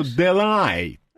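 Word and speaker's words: Delahaye: Делае, да? – Ну, случайно подожгли, да. – Как? Дела... – Делае, да. – Ну, бывает так. Как правильно произносится? Потому что если французы, то Делай.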